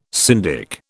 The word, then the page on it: syndic: British English